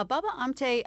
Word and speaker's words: Baba Amte: So tell us about Baba Amte, if I'm saying it correctly. – Yeah, Baba Amte, I think what you're thinking of, why he seems unlikely, is that he was born into a very aristocratic family.